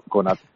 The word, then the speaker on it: Konaté: No sé si es Konaté o Konate, no sé muy bien cómo se pronuncia realmente. – Pues la verdad que tampoco me he puesto mucho, pero Konate. – Vamos Konate. A darle Konate de momento, sin el acento.